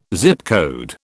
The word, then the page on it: Zip code: British English